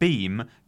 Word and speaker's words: Beahm: How do you pronounce his surname? B-E-A-H-M. Beam? Beam? Beam. – Beam. – Beam? – Beam. – We'll just say Guy Beam.